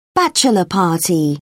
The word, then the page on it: bachelor party: British English